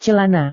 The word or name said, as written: celana